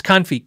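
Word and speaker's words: CuNiFe: It's not knife. It's confi. Confi. I think that's the actual pronunciation.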